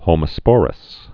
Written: homosporous

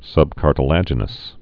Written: subcartilaginous

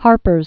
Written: Harpers Ferry

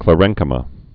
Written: chlorenchyma

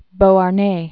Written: Beauharnais, Alexandre de

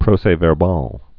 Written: proces-verbaux

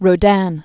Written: Rodin

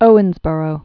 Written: Owensboro

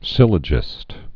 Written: syllogist

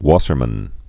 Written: Wassermann reaction

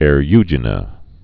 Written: Eriugena

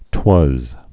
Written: twas

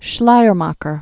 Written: Schleiermacher

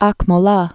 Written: Aqmola

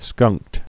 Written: skunked

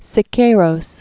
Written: David Alfaro Siqueiros

